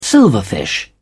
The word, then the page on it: silverfish: British English